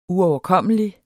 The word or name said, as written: uoverkommelig